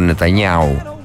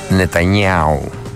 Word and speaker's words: Νετανιάχου: Ο Ρετζέπ Ταϊπ Ερντοάν ζήτησε, μάλλον οι υπηρεσίες, οι δικαστικές υπηρεσίες της Τουρκίας ζήτησαν να συλληφθεί ο Νετανιάχου, ο Νετανιάου που λένε και οι Ισραηλίτες και οι Ισραηλινοί και οι Εβραίοι, και όσοι ξέρουν πως προφέρεται το όνομα του Πρωθυπουργού του Ισραήλ, λέγεται Νετανιάου.